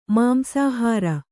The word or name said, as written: ಮಾಂಸಾಹಾರಿ